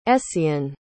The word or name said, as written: essien